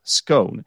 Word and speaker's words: scone: to feed two birds with one scone. And for me to really memorize it, it really helped me that the word scone rhymes with stone, you know?